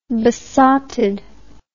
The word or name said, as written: besotted